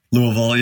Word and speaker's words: Louisville: uh Do you say it like Louisville now? – Louisville, yeah.